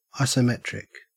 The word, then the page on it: isometric: Australian English